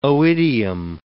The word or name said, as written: oidium